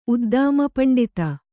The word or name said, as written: ಉದ್ದಾಮಪಂಡಿತ